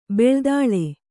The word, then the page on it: ಬಿಳಿದಾಳೆ: biḷidāḷe